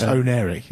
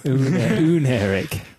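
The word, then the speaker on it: Oneiric: That'll be the last one before Oneric releases, I guess. – Ooh. Does that mean we can stop having to say... – No, it's Oneric. It's not ooh. – Oneric.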